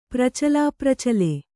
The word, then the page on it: ಪ್ರಚಲಾಪ್ರಚಲೆ: kannada